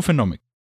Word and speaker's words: Phenomic: Wie spricht man das aus? Phenomic, oder was? – Mhm. – Einfach nur Phenomic.